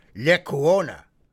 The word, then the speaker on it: Lecuona: The Lacuno, the Cuban boys, maybe I didn't pronounce that. Let me get that bit straight. Lecuona, Cuban boys, there we go.